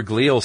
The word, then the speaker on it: glial: Specifically, the glial, G-L-I-A-L is how I'm pronouncing it, glial cells? – Are, yes. – Is that correct? – Yeah, you're right.